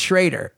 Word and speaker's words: Schroeder: Well, it's pronounced Schrader. It's spelled okay. Schroeder, but they pronounce it Schrader.